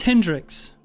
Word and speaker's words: Hendricks: Kendricks, I thought it was Kendricks? Holy crap, I thought it was Hendricks the whole time. – Was it Hendricks or Kendricks? – It is Niklaus Hendricks. Niklaus is spelled N-I-K-L-A-U-S.